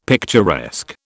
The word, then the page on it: picturesque: British English